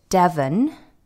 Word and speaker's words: Devon: And we all moved to Devon. I'm pronouncing it quite hard there because oftentimes people think I say Dublin in Ireland.